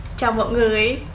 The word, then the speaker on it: Chào mọi người: Chào mọi người.